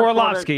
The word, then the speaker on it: Orlovsky: I think his name is Orlovsky. I'm sorry if I'm butchering his name. But Orlovsky, yeah, Dan Orlovsky, yep.